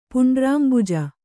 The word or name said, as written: ಪುಂಡ್ರಾಂಬುಜ